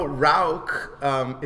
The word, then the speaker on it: Rauch: How do you say Bach in German? It's Bach. – Bach. Yeah. – Same one. – German. – It's Rauch.